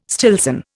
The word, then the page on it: Stillson: Indian